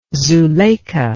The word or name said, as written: zuleika